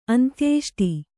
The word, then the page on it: ಅಂತ್ಯೇಷ್ಟಿ: Kannada